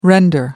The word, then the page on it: render: American English